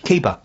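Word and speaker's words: KEiBA: Swan Staff Recruitment in Dartford picked up three prizes at the, how do you pronounce it when it's not the whole words? Kiba. Is it Kiba? – Kiba. – Kiba. – Yeah.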